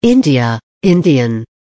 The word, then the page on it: India Indian: American English